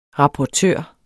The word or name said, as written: rapportør